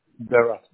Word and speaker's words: Berat: M-A-L-T-B-Y. – Shelton. Got it. Okay. And then you said your first name is spelled with a T, right? B-A-R-A-T? Is that right? – Berat. B-E-R-A-T. B-E-R-A-T. – B-E. Got it. B-E-R-A-T.